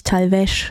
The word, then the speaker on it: Talvez: Natürlich Talvez ausgesprochen, nicht Talvez, wie ich es eben anmoderiert habe, von AO aus dem am 13.